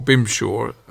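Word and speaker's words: Bemsha: Denzel Best, he came from Barbados, and it seems Bempshire, or Bimshaw, depending how you say it, was a sort of nickname for Barbados.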